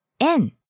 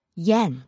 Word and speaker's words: 円: 百円玉 And I think Japanese people pronounce as en, not yen.